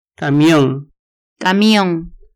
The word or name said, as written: camion